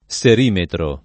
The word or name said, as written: serimetro